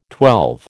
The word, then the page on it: twelve: American English